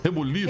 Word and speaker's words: rebuliço: fui incumbido de fazer todo todo reboluço, como é que fala? Rebuliço. Rebuliço. Rebuliço aí pro nosso MD de Valdinho.